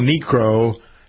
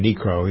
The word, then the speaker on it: Niekro: Speaking of that, passing this past weekend, Phil Niekro, some people pronounce it right, Niekro, I always call him Niekro.